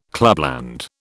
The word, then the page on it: clubland: British English